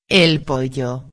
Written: el pollo

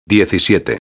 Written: 17

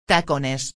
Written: tacones

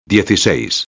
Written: 16

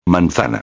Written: manzana

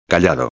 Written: callado

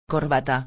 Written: corbata

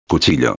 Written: cuchillo

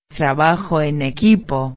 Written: trabajo en equipo

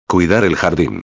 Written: cuidar el jardin

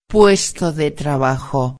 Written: puesto de trabajo

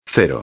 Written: 0

